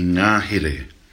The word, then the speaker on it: Ngāhere: N-G long A-H-E-R-E, Ngāhere. And Ngāhere literally means the forest or the bush, the woods.